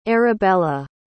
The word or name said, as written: Arabella